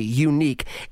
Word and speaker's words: Yuneec: The company is called Unique. I love the way they spell it. Y-U-N-E-E-C, Unique.